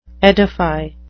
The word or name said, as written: edify